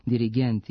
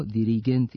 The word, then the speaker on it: dirigent: Zbog toga se oblici sa kratkosilaznim akcentima na posljednjem slogu, dirigent i asistent, akcentuju kao dirigent i asistent sa kratkouzlaznim akcentima na pretposlednjem slogu.